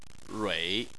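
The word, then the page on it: rui: tone 3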